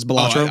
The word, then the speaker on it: Balatro: I've been calling it Baltero. I don't know if that's how it's pronounced. It's Balatro.